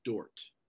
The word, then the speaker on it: Dordt: About 10 years later, the Dutch church convened a synod of Dort. – Alcuni anni dopo, gli olandesi hanno indotto il sinodo di Dort. – Dort is spelled D-O-R-D-T. – Si scrive D-O-R-D-T.